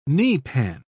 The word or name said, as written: kneepan